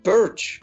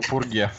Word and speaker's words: Purge: значит, по-американски это будет звучать как perch, а по-британски, ну, а по-британски чуть-чуть более чопорно. Пурге.